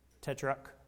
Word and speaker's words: Tetrarch: See, John took a stand against Herod the Tetrarch. Did I say that right? Tetrarch. Yeah.